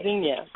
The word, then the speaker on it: Tzynya: Tzynya, but so, it's hard to remember to get to work there in the mouth.